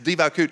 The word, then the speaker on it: devekut: D-E-V-E-K-U-T, but it's pronounced divakut.